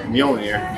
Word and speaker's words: Mjolnir: I also have my mighty hammer Mjolnir. – Mm-hmm. I'm impressed that you said that right too, because I'm kind of a comic book nerd and I could never pronounce Mjolnir, Thor's hammer.